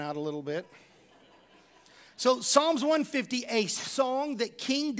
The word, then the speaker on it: Psalms: Now, the book of Psalms in the Old Testament starts with a P, so it'll throw you off if you're trying to learn how to spell it.